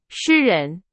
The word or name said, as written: 诗人